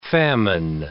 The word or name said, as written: famine